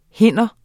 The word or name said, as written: hinder